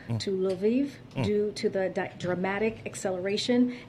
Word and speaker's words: Lviv: From the capital of Kyiv to Lviv? I don't know how to... I'm butchering these names, but... – Kyiv? – Yeah. – Lviv? – Yep.